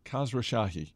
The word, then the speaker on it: Khosrowshahi: It's, and I'm going to botch this pronunciation, so bear with me. Dara Khosrowshahi. Khosrowshahi. – I think I got that. That's right.